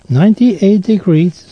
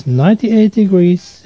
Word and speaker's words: 98 Degrees: En dat was door 98 Degrees. En heb ik het opgezocht en zo moet ik het uitspreken, want het is een Engelse groep. Dus het was op de derde plaats, 98 Degrees, and Because of You.